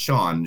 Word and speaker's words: Sian: You can find them online at Sean's Sushi, which is S-I-A-N, but it is pronounced Sean.